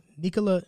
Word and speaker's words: Nikola: And his name is not Nikola. It's Nikola. It's like Nikola. – It's Nikola. – Nikola Jokic. – Like a double E.